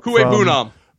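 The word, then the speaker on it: Okwuegbunam: Kuei-Bunam.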